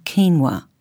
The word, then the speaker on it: quinoa: The United Nations, or UN as it is commonly referred to, has declared 2013 the International Year of Quinoa. The word quinoa is spelt Q-U-I-N-O-A and is pronounced quinoa.